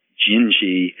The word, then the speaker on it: Dindi: The song is spelled, D-I-N-D-I. It is pronounced Brazilian Portuguese. It's pronounced Gingy.